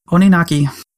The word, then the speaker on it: Oninaki: Oninaki. – Ooh, good job. Oninaki.